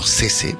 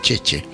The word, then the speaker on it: Cece: C-E-C-E, su nombre italiano. C-E-C-E, como Cese, pero se pronuncia Cheche.